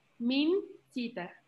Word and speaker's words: Mincita: Eh, se pronuncia Nana como Nana normal, Mincita. También es un poco difícil.